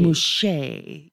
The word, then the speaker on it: muxe: It's spelled M-U-X-E. – M-U-X-E-S. – E-S. Mouché. – Mouché.